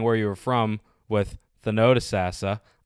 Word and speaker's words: Thonotosassa: Thonotosassa.